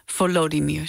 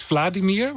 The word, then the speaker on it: Volodymyr: Het verhaal van de vikingkoning van Kiev, Volodymyr. Zeg ik dat goed? – Ja, ik had eerst Vladimir, want ik was er al mee begonnen.